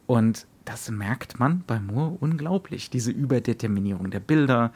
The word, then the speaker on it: Moore: Wie ja, also wenn wem äh, Alan Moore oder Moore, eigentlich Moore ausgesprochen,